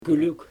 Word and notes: kylyg: Xyzyl

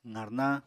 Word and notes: qarnañ: apatani

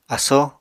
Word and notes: asó: Apatani

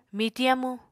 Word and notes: mitiñ amu: Apatani

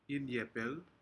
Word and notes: inyepec: Aneityum

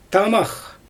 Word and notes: tamax: Xyzyl

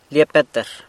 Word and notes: Lia Petr: sanlucasquiavini

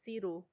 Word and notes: siru: Macuiltianguis

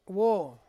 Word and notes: wo: Ansus

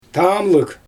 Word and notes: tamlɯg: Xyzyl

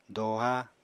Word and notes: doha: apatani

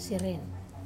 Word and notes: seren: Wamesa

